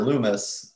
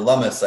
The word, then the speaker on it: Lummis: And Will, I've talked to a few times, Will Cole, and, you know, we just need him to have his relative, Senator Loomis, or Lummis, I think it's pronounced like, yes, hummus, I just learned, you know, give a call down to the Texas legislators and see if we can get stuff moving.